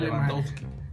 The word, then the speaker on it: Lewandowski: ¿Cómo se pronuncia, güey? – Sí, güey, Lewandowski.